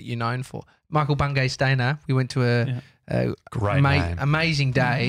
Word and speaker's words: Stanier: It's Stainer. – Stainer. – It's spelled S-T-A-N-I-E-R.